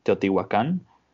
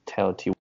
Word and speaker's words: Teotihuacan: So in Spanish is pronounced Teotihuacan. Yeah. And in English would be kind of like Teotihuacan, right, maybe.